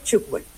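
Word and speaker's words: tchoukball: Bueno, primero entonces se pronuncia chukbol. – Ah, vea pues. – Bien. Cuando tengas la oportunidad, el balón cuando rebota contra una malla, contra un marco, hace ese sonido como ch. Entonces por eso se pronuncia y se denomina así, chukbol.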